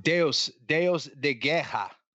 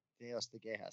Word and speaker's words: Deus de Guerra: Spell it. – Yeah. D-E-U-S, then D-E, and then G-U-E-R-R-A. There you go. – Oh, is that right? – Yeah. – No? – Deus. – Oh, yeah. – D-E-U-S. Deus. – That's right. – No, don't. – Yeah. D-E. Yeah. And then G-U-E-R-R-A.